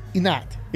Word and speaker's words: Inat: We've got Inat life. Inat vibes. Apparently, we're pronouncing that wrong. What do you want from it? – We're pronouncing it not wrong? – A short A, Inat. – Inat? – Inat.